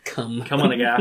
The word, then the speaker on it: Kumagai: Playing the role of Kim, that was Denise Kumagai, I believe is how it's pronounced. – Come. – Come on, a guy.